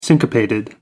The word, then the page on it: syncopated: American English